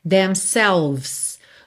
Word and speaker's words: themselves: Some fools fool themselves, I guess, themselves. Do V você vai pro S, tá? Não pronuncie essa letra E.